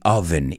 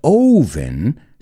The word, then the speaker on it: oven: So baking and broiling are what we do in the oven. Y cuidado con la pronunciación. No es oven, sino oven.